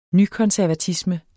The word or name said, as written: nykonservatisme